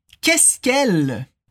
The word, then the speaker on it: qu'est-ce qu'elle: Attention à la prononciation, on dit qu'est-ce qu'elle Qu'est-ce qu'elle Comme si c'était un seul mot.